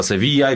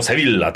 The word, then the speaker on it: Sevilla: Okej, no to teraz ta Sevilla, jak pani mówi, choć Polacy powiedzą Sevilla, tak? Sevilla. Dwie L-ki, język hiszpański czyta się jako i dłuższe troszeczkę i Sevilla, dlatego.